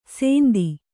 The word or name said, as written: ಸೇಂದಿ